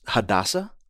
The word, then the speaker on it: Hadassah: I believe the name is pronounced Hadassah. – Exactly, yes.